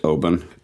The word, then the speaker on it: Oban: It's not an island whiskey, but it is definitely coastal, and it is pronounced Oban.